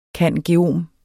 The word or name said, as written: cand.geom.